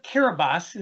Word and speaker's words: Kiribati: So the island of Kiribati is how they pronounce it there.